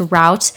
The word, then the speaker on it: route: route in order. I don't know how. I know some people pronounce it as root and others pronounce it as route.